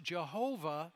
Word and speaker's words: Yahweh: But there are only consonants in the Hebrew language, and so it can be pronounced Jehovah or Yahweh, either one in the Hebrew language.